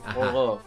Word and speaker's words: Forró: De hecho se pronuncia como la R entre una R y una G. Forró. Una cosa así.